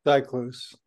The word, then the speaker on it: Zyklus: And he was playing the Karlheinz Stockhausen piece, Zyklus or Zyklus, however you want to pronounce it. – Zyklus, yeah.